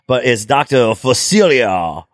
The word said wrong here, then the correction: Facilier: Oh yeah. – that's oh, Facilier.